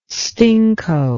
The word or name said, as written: stinko